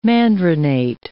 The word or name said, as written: mandarinate